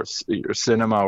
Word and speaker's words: Sinema: So as long as they can get Manchin or Sinema or whatever to, did I say her name right?